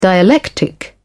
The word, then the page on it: dialectic: British English